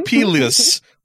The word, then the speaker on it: Pyles: Pyles.